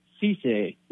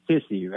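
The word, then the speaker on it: Cissé: Now, it's pronounced Cisse, not Sissy, right? – Yeah, Cisse.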